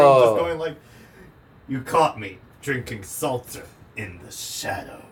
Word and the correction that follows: seltzer: Seltzer. – Yeah, people pronounce it both ways. I've heard people pronounce it both ways. – I say seltzer. Sorry. – I say seltzer.